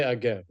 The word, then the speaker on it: Naif Aguerd: Say Aguerd, like in French. – Yeah, I didn't know if it had more of an Arabic pronunciation, but whatever.